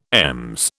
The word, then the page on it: EMS: British English